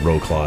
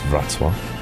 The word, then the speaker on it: Wrocław: So it's like row claw. That's how everyone pronounces it, but it's Wrocław.